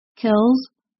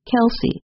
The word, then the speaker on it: Kelce: Whether Kelce should be pronounced with one or two syllables, Kels or Kelce, has been a subject even his own family talks about. Both Travis and his brother, Philadelphia Eagles center Jason Kelce, say their last name as Kelce.